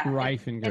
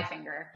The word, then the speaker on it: Reifinger: Reifinger. – It's okay. – Reifinger.